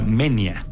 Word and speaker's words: Moenia: Ya luego después nos enteramos que se pronuncia Menia, porque es diptongo, entonces va junto.